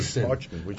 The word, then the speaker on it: Basin: Emailer wants to know this emailer claims you said something about a Basan reserve. What did you say? Which reserve? Basin. Uh, it's called Herzog Single Vineyard Basin. How do you spell it? B-A-S-I-N. – B-A-S-I-N.